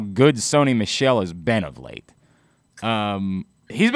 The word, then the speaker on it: Sony Michel: Like you said, and you said it correctly, for the last 3 weeks, Sony Michel,